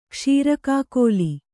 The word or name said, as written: ಕ್ಷೀರಕಾಕೋಲಿ